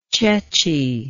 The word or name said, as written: ceci